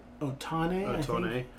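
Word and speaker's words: Ohtani: It should be Shohei Otani. How did I pronounce it? – Otani.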